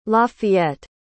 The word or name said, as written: Lafayette